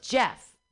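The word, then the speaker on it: Geoff: Oh, G-E-O-F? – Yeah. – Or G-O-F. Signed up. That's pronounced Jeff. – Right. But it wasn't his name. – No, he wrote J-E-F-F.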